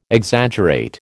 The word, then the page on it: Exaggerate: American English